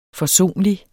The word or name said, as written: forsonlig